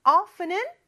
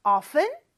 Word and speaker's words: often: 뭐 이런 말이었는데 visit이 뭐 물론 방문하다 라는 좀 딱딱한 말도 되지만 그냥 가다 라는 의미로 많이 쓰이고 often은 종종이라는 뜻이고 발음은 두 가지가 있다고 했죠. often, t 발음 없이 할 수도 있고 often, t를 발음할 수도 있다. 이렇게 말씀드렸어요.